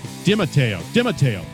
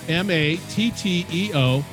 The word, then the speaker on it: DiMatteo: Anyway, her name is Sandra DiMatteo. That's DiMatteo. Come on. Did I get it close? – You had the Italian pronunciation.